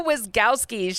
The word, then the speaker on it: Wyzgowski: It is Sophia Wyzgowski.